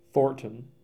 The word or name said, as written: Thornton